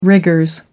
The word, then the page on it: rigors: British English